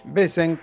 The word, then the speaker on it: Wesen: Besen, que se escribe Wesen, es una palabra alemana que quiere decir esencia.